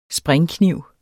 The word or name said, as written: springkniv